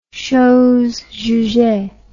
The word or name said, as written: chose jugee